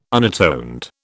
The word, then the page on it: unatoned: British English